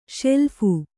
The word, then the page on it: ಶೆಲ್ಫು: śeḷphu